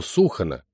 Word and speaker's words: Сухана: Дело в том, что Сухана, с ударением на «у», кстати, не судоходна на всем протяжении.